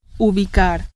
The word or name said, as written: ubicar